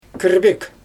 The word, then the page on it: kIrbIk: Xyzyl